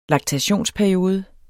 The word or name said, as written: laktationsperiode